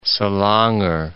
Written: Selangor

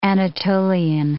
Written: Anatolian

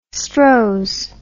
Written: Strohs

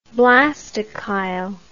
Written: blastochyle

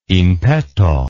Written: in petto

